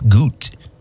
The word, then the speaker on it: Gutt: And I've always said Jeff Gutt's name wrong. It's Jeff Gutt.